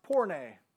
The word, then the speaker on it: porne: The word harlot in Hebrews 11:31 is a Greek word that is pronounced porne.